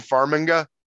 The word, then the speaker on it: Farmiga: Farmiga.